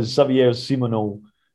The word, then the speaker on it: Xavier Simoneau: But it's more spread out maybe than some of the others that has been above, which leads us to Xavier Simoneau.